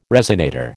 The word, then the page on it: resonator: American English